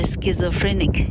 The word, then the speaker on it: schizophrenic: Schizo S C H I 才会念作 ski S C H I Zo Z O Free P H R E Nick N I C 所以 schizophrenic 叫做精神分裂 schizophrenic schizophrenic，好，那要讲讲人家是神经病呢，英文有非常多种的说法哈。